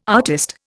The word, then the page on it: artist: Indian